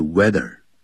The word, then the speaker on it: whether: 这个不要念的 whether, 要念的 weather。 H 是不发音的。